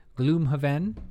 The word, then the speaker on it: Gloomhaven: I think that's how you say it. Gloomhaven. You have you heard of this? You heard of this property? You're, you're definitely saying it right.